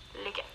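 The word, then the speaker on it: lykke: But you do pronounce it, licka.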